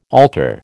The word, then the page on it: alter: American English